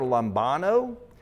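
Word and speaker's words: lambano (λαμβάνω): That are basically pronounced something like this. There's a Greek word lambano, and then there's a Greek word dekomai.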